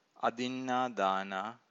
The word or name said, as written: adinnadana